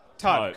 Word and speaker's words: taupe: I was like, am I saying it right? – Yeah. I don't know. Do you guys know? Taupe, cool, yeah, cool. – Taupe rhymes with grope.